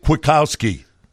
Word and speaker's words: Kwiatkowski: Sorry, going to miss this guy, Nick Kwiatkowski. – Ah, you said his name right.